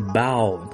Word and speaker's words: bow: 落山了，那这个是这个却是 bow，它是取的是这个呃鞠躬低头的这个意思，发音不一样哦。